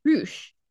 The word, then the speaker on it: Rush: Roosh. – Okay. – Even though it's spelled Rush, but it's Roosh, apparently.